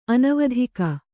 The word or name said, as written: ಅನುವಾದಕ